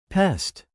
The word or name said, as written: pest